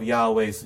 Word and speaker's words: Yahweh: Well, in Hebrew, since the verb form tells us the correct pronunciation, to discover the correct pronunciation of Yahweh's name, we only need to figure out what verb form is being expressed with the word Yod-Heh, Wow-Heh, the four letters in Hebrew, which I believe is pronounced Yahweh.